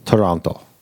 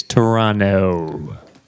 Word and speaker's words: Toronto: Toronto. – You say it wrong. Because they told us, as locals do, you don't pronounce that last T. It's Toronto.